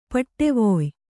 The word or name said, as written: ಪಡುವಾಯ್